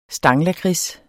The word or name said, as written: STANGLAKRIDS